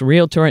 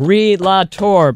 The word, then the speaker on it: realtor: There is no extra la. He says it's just realtor instead of re-la-tor.